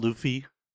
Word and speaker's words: Luffy: One Piece is about Luffy.